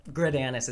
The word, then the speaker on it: Greidanus: Gridanus is how I say it because I don't know if I already said this, but I want to de-emphasize the anus.